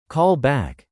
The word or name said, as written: call back